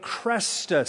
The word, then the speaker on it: Chrestus: It said that he did it over a man by the name of Crestus, C-H-R-E-S-T-U-S, we would spell it in English, Christus.